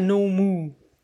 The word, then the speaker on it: NOMOO: Wo kommt denn eigentlich der Name her, No Moo? Ja, du hast es schon richtig ausgesprochen.